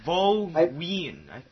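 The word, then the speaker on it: Võ Nguyên Giáp: I'm going to go with Vo Nyan Giap. – Nyan, that's good. Is it Nyan? – Vo Nguyen. – What? Nguyen? What do you no.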